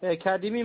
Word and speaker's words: Khadimi: Mon nom de prénom, c'est Kadimi Mohsen. – Et comment ça s'écrit? – K-H-A-D-I-M-I.